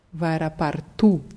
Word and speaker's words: аэропорту: Vairapartu. Die Betonung bleibt trotzdem auf der letzten Silbe.